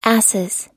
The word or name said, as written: asses